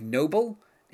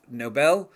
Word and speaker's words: Nobel: I don't think he pronounces it Nobel. He says like noble instead of Nobel.